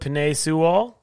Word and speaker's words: Penei Sewell: I got, can't even pronounce his name, but Penei Sewell.